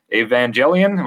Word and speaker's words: Evangelion: Like, is this Evangelion?